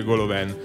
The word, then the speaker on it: Golovin: Mi dici esattamente la pronuncia di Golovin qual è? – Vabbè, questa puntata ormai è definitivamente andata in barca. Visto che ho studiato che Golovin ha origini francesi, si dice Golovin.